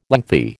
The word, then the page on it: lengthy: American English